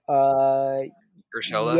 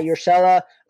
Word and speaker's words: Urshela: Urshela. – Yeah, Urshela.